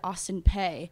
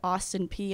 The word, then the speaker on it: Austin Peay: Everybody back home, they called it Austin Peay. And it wasn't until the first time I spoke to her on the phone that she said Austin Peay.